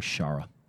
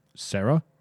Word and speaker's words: Shara: No. There's an H in there. Shara. – Shara. Like yeah. Sahara, but we're going to pronounce it Shara. – No, because there's no A between the S and the H. It was S-H-A-R-A, Shara. Yeah.